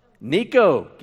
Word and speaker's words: Necho: His name was Necho. Giddy up. He wasn't Hispanic, spelled with an E, not an I.